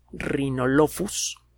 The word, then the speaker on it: Rhinolophus: El eh, nombre formal del género es Rhinolophus. La F se escribe como PH, Rhinolophus R-H-I-N-O-L-O-P-H-U-S.